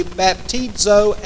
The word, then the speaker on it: baptizo: Now, those two little strange words right there are pronounced it should be baptizo and baptizain.